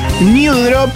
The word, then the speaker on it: New Drop: Se llaman New Drop, pero se escribe así como suena, con N,